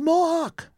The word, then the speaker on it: Mohawk: Mahawk. – Yeah, they gave him both a citation and a violation. I'm sorry. – Citations and violations. And it's pronounced Mohawk.